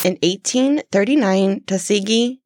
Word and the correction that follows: Tosagi: did I say it right? – No. – Say it again. – Tosagi.